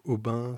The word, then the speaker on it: Aubin: Donc Aubin, c'est mon prénom et mon nom d'artiste. Ça s'écrit A-U-B-I-N. Musique, ça s'écrit M-U-S-I-C.